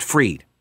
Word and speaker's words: Fried: but the second vowel is pronounced in the name with Germanic derivation, F-R-I-E-D, is pronounced Freed." Yes, I am aware that everyone is pronouncing it Freed, but I don't like pronouncing it that way.